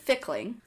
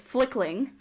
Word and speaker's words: Fickling: His name is Joseph Fickling. I thought it was Flickling at first.